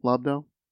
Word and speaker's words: Lobdell: Is that how you say his name? Lobdell? – Yes. – Okay. I, I never knew if we were right. Yeah, um, like if you lob a ball.